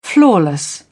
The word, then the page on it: flawless: British English